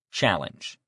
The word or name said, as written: challenge